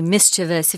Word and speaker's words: mischievous: You need to make sure you say mischievous. If you say mischievous, people will know that you have good English, which is great.